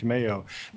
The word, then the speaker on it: Meo: And so, did you say his last name, Mayo? – Oh, Mayo. – Mayo. Yeah. And it's spelled M-E-O, Eric Mayo.